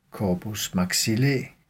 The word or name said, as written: corpus maxillae